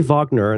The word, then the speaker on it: Wagner: Do you pronounce it Kurt Wagner or Wagner? I say Wagner.